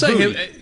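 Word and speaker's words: Boutte: It's not Booty, it's Booty.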